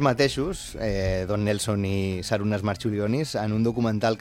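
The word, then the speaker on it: Marčiulionis: Marciulionis. Perquè que aquesta C es pronuncia com una TX.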